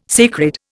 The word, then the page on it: sacred: Indian